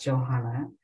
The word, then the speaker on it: Johanna: I just want to offer that my I love being referred to as Jay, and my name is pronounced Johanna, like Johannesburg, South Africa.